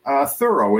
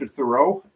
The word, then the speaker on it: Thurow: Thurow is how I pronounce it, but I'll answer to Thurow. Whatever you want to say, it's fine. Thurow is good.